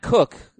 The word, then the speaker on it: Koch: Matt Cook, yes, that's how it's pronounced, K-O-C-H, Matt Cook, will replace him in the Arizona rotation.